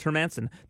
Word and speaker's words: Hermanson: But let's start off with UFC in Fort Lauderdale, Florida, the BB&T Center, as Jack Hermanson, as John Anik says on Twitter, they asked Jack how it's pronounced, and it's pronounced Hermanson.